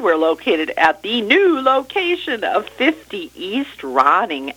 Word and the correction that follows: Rooney: Avenue. Oh, actually, it's 50 East Rooney.